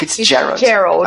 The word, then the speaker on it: Fitzgerald: Fitzgerald.